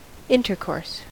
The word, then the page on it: intercourse: American English